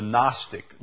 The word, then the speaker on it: Gnostic: The Gnostic, the word is spelled G-N-O-S-T-I-C, Gnostic.